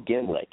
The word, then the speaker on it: Gehmlich: Uh, Rachael Gehmlich.